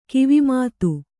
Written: ಕಿವಿಮಾತು

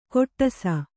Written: ಕೊಟ್ಟಸ

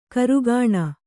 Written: ಕಾರಕೂನ

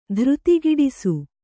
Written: ಧೃತಿಗಿಡಿಸು

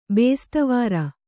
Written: ಬೇಸ್ತವಾರ

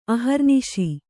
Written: ಅಹರ್ನಿಶ

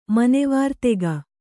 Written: ಮನೆವಾರ್ತೆಗ

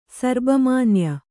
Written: ಸರ್ಬಮಾನ್ಯ

